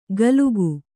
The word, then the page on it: ಗಲುಕು: galuku